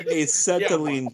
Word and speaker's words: acetylene: Well, he was holding a saline torch, so. – Can't forget saline torch in a gunfight. Watch me. – It's pronounced acetylene torch. – Acetylene. – Acetylene torch. – Acetylene torch. Okay.